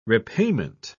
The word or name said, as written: repayment